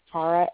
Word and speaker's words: Saqqarah: So my name is spelled Sakara, S-A-Q-Q-A-R-A-H.